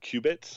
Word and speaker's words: qubit: So they're pronounced qubit.